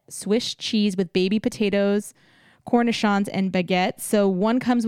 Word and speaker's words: cornichons: And Cornichons. – Cornichons.